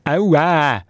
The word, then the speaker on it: Aua: Und heute Abend geht es unter anderem um ein Wort mit drei Buchstaben. Aua. Ausgesprochen aber mit etwa 4 Ä am Schluss. Aua.